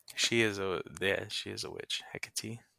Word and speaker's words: Hecate: It's interesting you pronounce it like that, because I've always heard it as Hecate. Hecate.